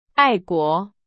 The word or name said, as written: 爱国